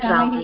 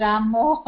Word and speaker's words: Ramayana: but that is Ramayana you need to pronounce that Ramayana yes So I was just confused because I okay. all the time I learnt it as Ramo.